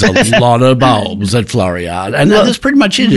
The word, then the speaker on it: Floriade: With Florida. How do you pronounce it? Floriade.